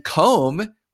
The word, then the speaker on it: comb: Well, come is spelled C-O-M-E, and comb with a long O has a B at the end of it that you can't even hear.